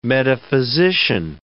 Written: metaphysician

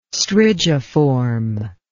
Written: strigiform

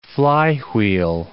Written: flywheel

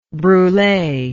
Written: brule

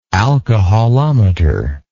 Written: alcoholometrical

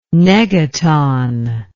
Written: negaton

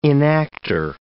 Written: enactor

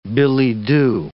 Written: billet-doux

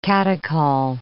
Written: catechol

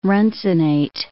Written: runcinate

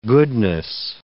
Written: goodness

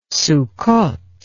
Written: succoth

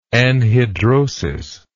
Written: anhidrosis

